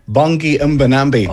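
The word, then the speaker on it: Bongi Mbonambi: Bongi Mbanambi.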